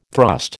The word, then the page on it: thrust: American English